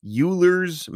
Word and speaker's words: Euler: And I think you were going to talk about Euler's method or Euler's, not Euler, Euler's method. – Euler's method. – It's spelled Euler's. It is. But it's pronounced totally Euler's.